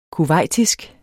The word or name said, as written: kuwaitisk